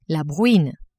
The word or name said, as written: bruine